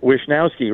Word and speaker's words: Wishnowsky: Good job there, Susan. Wishnowsky, right. That's how you say it.